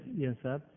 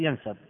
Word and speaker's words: ينساب: كثرت الأسئلة يا شيخ حول شركة ينساب. – نعم. – لا أعلم إذا كان النطق صحيح لهذه الشركة أو لا. – هي شركة ينساب. – نعم، شركة ينساب.